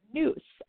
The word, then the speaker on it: nous: So the Greek word for mind in this context is the word nous, and it's spelled N-O-U-S.